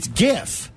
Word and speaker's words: GIF: So it's graphic starts with a real, like the hard G. It's GIF. We say it like graphic.